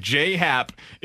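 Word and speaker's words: J.A. Happ: On his Baseball Reference page, it says J, J-A-Y. J-Hap is how you pronounce it, the gentleman's name.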